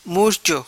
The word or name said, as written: Murcho